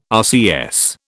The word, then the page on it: RCS: British English